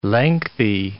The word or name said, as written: lengthy